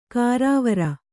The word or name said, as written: ಗರವರ